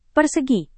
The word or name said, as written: persegi